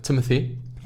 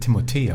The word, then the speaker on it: Timothée: Ist der mit Timothy? – Mit Timothy. Ich habe letztens gelernt, dass der Timothée ausgesprochen wird. – Ja. – Weil ein Franzose ist.